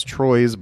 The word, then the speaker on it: Troyes: So Troyes, T-R-O-Y-E-S, in case you want to look it up. It looks like it's Troyes, but it is a French village and it's pronounced Troyes.